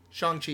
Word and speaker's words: Shang-Chi: So are you going to tell me that you guys are going to do this thing with, how do you say it, Shang-Chi? – Shang-Chi, yeah.